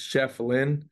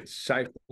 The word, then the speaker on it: Schieffelin: And then I got here and uh, people really just didn't know how to pronounce my last name. And it's just Chef Lynn.